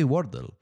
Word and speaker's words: Wordle: Se dice así, Wordle.